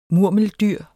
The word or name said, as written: MURMELDYRENES